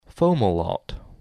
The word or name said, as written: Fomalhaut